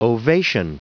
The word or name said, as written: ovation